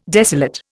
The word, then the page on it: desolate: Indian